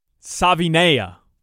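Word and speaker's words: Savaiinaea: You have to pronounce the last name for me because I still haven't gotten it down. – I don't even want to give it a chance because I have absolutely no idea how to pronounce it. – Savinea. – I think you nailed it.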